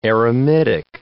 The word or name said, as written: eremitic